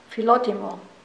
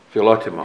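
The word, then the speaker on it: Philotimo (φιλότιμο): Pressure the old man within with, now you, how do you pronounce that? – Philotimo. – Philotimo.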